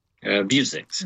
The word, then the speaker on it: Vuzix: Vuzix.